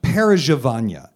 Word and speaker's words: Переживание: I just learned how to pronounce this recently, despite writing this book, is Переживание, and it's a Russian word that loosely translates to experiencing or re-experiencing.